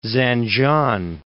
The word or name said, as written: Zanjan